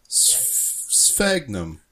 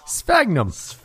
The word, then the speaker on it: Sphagnum: Sphagnum. – Yeah. – Sphagnum.